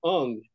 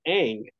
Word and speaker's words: Aang: Night Shyamalan made that terrible last, the Airbender movie, and he was calling him Ung. The kid's name is Aang.